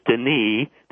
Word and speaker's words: Denis: You can access him very simply by Googling his name, which is Denis, the S is silent, D-E-N-I-S, Rancourt, the T is silent, R-A-N-C-O-U-R-T.